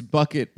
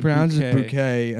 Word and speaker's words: Bucket: Her last name's Bucket, but she pronounces it Bouquet, and then she's like ashamed of her white trash family.